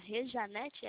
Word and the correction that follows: Rejane: é isso? – Não, Rejane? – Rejane. – Rejane, é isso aí.